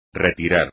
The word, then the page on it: retirar: espagnol